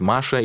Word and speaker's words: Маша: Мне было проще изменить имя, пока я училась в Америке, чем пытаться каждого встречного научить произносить Маша или Мария правильно. А фамилия моя Соколова, в переводе на английский и есть Хоук». «Ма-ша», — по слогам протягиваю я, примиряясь. И совсем не сложно.